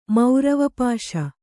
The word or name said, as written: ಮೌರವಪಾಶ